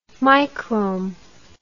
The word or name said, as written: microhm